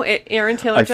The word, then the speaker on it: Aaron Taylor Johnson: No, Aaron Taylor Johnson.